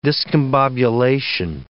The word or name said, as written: discombobulation